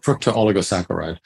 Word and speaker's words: fructooligosaccharide: Now, FOS is an acronym for a very complicated molecular, is it a fructooligosaccharide, or what's the pronunciation of that? – You pretty much got it, fructooligosaccharide.